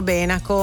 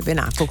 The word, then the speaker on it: Benaco: Tanti lacustri, infatti, dicono Benaco. La corretta pronuncia è Benacco, come giustamente ha detto Franco.